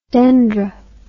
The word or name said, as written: dendr